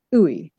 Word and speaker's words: Ooi: You can find it by searching for Creative Conversations and my surname, Ooi, and I'll spell that for you, O-O-I.